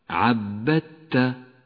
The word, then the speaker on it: عبدت: تدغم الدال الساكنة بالتاء التي بعدها فتسقط من اللفظ ولذلك لونت بالرمادي وأما التاء التي بعدها فتشدد ويصير النطق هكذا عبدت